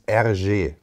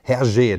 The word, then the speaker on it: Hergé: Und das wiederum französisch ausgesprochen ist RG. Und so ist der Künstlername von dem Mann entstanden. Aber ab jetzt nehmen wir nur noch Hergé, denn als solcher ist er hier bekannt geworden.